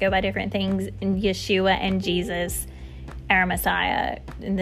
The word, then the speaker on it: Yeshua: And just a little caveat here, when you hear me say Yeshua, that's the Hebrew pronunciation of his name.